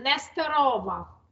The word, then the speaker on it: Nesterova: Valentyna, last name Nesterova.